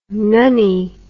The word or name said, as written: nunnie